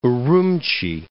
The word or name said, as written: Urumchi